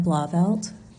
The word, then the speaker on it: Blauvelt: Janessa Blauvelt, J A N E S S A B L A U V E L T.